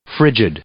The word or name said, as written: frigid